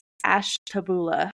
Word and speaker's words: Ashtabula: I think it's Ashtabula. Ignore the H. Ignore the U.